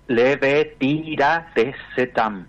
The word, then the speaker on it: Levetiracetam: ¿Me repite el nombre? – Levetiracetam. – A ver si lo dije bien. Levetiracetam. – Levetiracetam. Así, sería, escribimos de vuelta. Leve, así con B corta, ¿no?